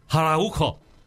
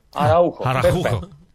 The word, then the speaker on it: Araujo: Ieri sera ho imparato la pronuncia corretta dagli spagnoli. Araujo. – Araujo.